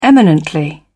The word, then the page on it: eminently: British English